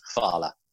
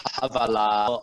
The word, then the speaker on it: hvala: Havala, or I didn't know how to pronounce it.